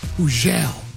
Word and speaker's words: Hugel: I told you, Hugel, the silent G, Hugel, is going to touch down right here on Hot 97, November 25th, Thanksgiving Eve, 5 p.m., Funk Flex, Bobby Trends, and Hugel.